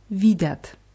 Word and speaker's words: видят: Ja und sie Plural sehen, они видят. – Они видят. – Видят, die Betonung ist auf i.